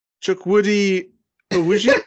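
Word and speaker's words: Chukwudi Iwuji: Chukwudi Awuji. – Yeah, he nailed it, mate. – He's a Nigerian British actor. – What did you call him? – Chukwudi Awuji.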